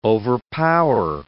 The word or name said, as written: overpower